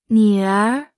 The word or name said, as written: 女儿